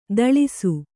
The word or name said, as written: ಥಳಿಸು